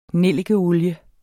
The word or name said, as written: nellikeolie